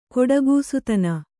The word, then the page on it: ಘಟಿಕಾಸ್ಥಾನ: ghaṭikā sthāna